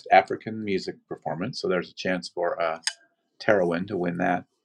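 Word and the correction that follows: Tinariwen: Tenarwin, sorry, pronounced that wrong.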